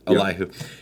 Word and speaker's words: Elihu: Elihu, is that how you pronounce his name? – Yes. – Elihu.